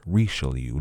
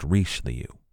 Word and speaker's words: Richelieu: While the Thirty Years' War was raging in Germany, the Huguenots in La Rochelle revolted against King Louis XIII and Cardinal Richelieu, which I have been pronouncing incorrectly. It's Richelieu, not Richelieu.